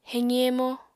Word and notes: heqi-hemo: apatani

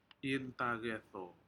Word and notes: intacedo: Aneityum

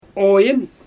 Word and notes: ojIn: Xyzyl

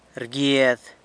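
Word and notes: rguiad: San Lucas Quiaviní Zapotec

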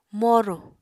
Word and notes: moru: Apatani

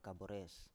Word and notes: kabores: Biak

